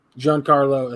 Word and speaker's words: Giancarlo: G- Giano, G- Giancarlo, Giancarlo, Giancarlo Esposito.